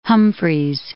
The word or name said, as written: Humphreys Peak